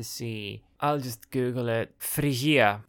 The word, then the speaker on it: Phrygia: I think in English it's pronounced Phrygia.